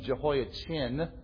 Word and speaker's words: Jehoiachin: If you know the name Jehoiachin, I'm going to pronounce it that way so that we'll specify which one we're talking about. Jehoiachin, his grandson is Zerubbabel, and he is appointed governor of Judea to oversee the rebuilding of the temple.